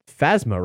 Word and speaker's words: Phasma: Also, I'm fairly certain that we can officially say the pronunciation is Phasma, right? Because yeah, it's Phasma. Based on Phantasm.